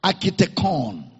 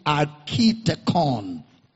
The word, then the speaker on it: architekon: Architekon. That's a Greek word. Architekon. It is spelled as A-R-C-H-I Architekon. T-E-K-O-N. Architekon.